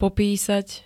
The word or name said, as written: popísať